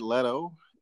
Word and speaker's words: Leto: So, Jared Leto. Is it Leto or Leto? – I'm gonna say his name.